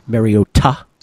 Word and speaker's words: Mariota: But I'm not a big Marcus Mariota guy, or as Mike Tirico insists on referring to him in the Hawaiian pronunciation, Mariota.